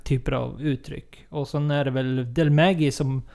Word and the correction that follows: Del Maguey: Del Maguey.